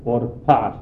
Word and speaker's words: Orpa: E non a caso costei si chiama Orpa, o Orpa secondo la doppia pronuncia della consonante, che significa colei che volta le spalle.